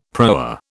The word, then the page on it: proa: British English